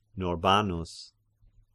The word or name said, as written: Norbanus